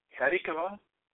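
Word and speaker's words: Hatikvah: And the pronunciation is this, Hatikava, H-A-T-I-K-V-O-H. H-A-T-I-K-V-O-H, Israeli National Anthem.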